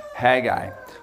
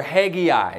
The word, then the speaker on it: Haggai: And you will land on Haggai or Haggai. It all depends how you say it. And I've my professors in college, said it both ways.